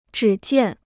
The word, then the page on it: 只见: zhǐ jiàn